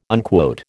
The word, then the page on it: unquote: American English